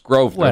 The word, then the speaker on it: Grosvenor: Grosvenor, it's pronounced Grosvenor.